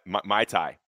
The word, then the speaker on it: Muay Thai: Am I saying that right? Muay Thai. There we go.